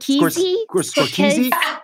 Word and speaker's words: Scorsese: Scorsese. – How do you pronounce it? – Scorsese.